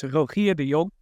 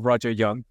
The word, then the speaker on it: Rogier de Jong: Well, as I'll pronounce my name, right? So my name is Rogier de Jong. Dutch is, and in English, I usually call myself Roger Jong. – So okay. – That's a literal translation of my name.